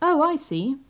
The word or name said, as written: oh i see